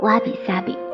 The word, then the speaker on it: 侘寂: 侘跟寂这两个字，我们有说过，侘寂是念作哇比萨比。